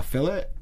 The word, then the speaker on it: fillet: When she served the food, she called it a fillet. – Yeah. – Not a fillet. – But that's how they pronounce it in, like, Australia and England and things. They say a fillet. Like I have friends from South Africa and things, and they say fillet.